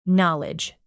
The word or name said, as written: knowledge